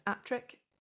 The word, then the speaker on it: Appletreewick: So Appletreewick is actually pronounced Attrick.